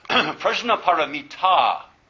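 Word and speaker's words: prajñāpāramitā: In the Buddhist texts, in the commentaries on this text, they say, well, prajnaparamita, the long A at the end, indicates a feminine noun.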